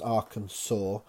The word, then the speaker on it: Arkansas: K-N-E-E, knee. Little Rock is the capital of which American state? Looks like Arkansas, but is pronounced Arkansas.